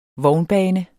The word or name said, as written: vognbane